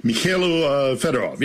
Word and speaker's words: Mykhailo Fedorov: Who is and what's the situation with Mykhailo Fedorov? Am I saying that right? – Mykhailo Fedorov, yes.